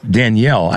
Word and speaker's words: Danielle: Daniel Butterfield, Danielle, I said this earlier, it's Danielle, I know it is, but Daniel and Danielle are so phonetically close, you got to get that right.